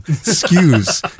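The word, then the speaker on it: Skuse: It's spelled nothing like it sounds because I'm pronouncing it wrong. Skews, Cole Skews. Sorry, I was giving you an extra R. It's S-K-U-S-E, which I didn't even think's a name for a human animal, but there he is.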